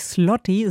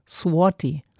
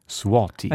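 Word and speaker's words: złoty: Genau, die Währung heißt auch nicht Zloty, sondern Swati. – Swati.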